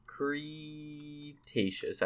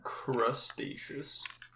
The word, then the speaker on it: Cretaceous: Jurassic World Camp Cretaceous. I believe that's how you pronounce that. – Crustaceous. – Nope. Nope. Crustaceous. Nope. No lobsters here.